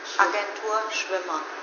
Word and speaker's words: Agentur Schwimmer: How to pronounce it in German? – Agentur Schwimmer. – Agentur Schwimmer.